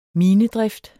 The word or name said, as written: minedrift